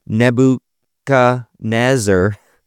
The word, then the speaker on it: Nebuchadnezzar: So the story of Daniel interpreting King Nebuchadnezzar.